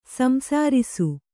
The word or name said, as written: ಸಂಸರಿಸು